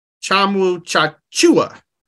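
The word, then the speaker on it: Tchamwa Tchatchoua: So, by the way, they were pronouncing his name a little bit differently on the broadcast, and I don't know if you saw my tweet. – I did. – Yeah. So, apparently, it's Jonathan Chamuchachua.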